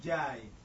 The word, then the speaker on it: Njai: The N is a silence. – Yeah. – Njai.